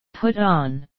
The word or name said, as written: put on